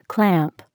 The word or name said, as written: clamp